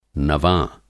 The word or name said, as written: नवाँ